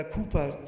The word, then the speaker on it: Cowper: Und dieser John Newton, dieser harte Knochen, wurde dann der beste Freund von diesem hochsensiblen Dichter, William Cowper, oder Cooper spricht man es aus,